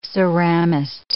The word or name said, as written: ceramist